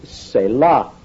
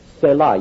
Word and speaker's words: Selah: That Hebrew word there is Selah. Selah, you spell that S-E-L-A-H. Selah.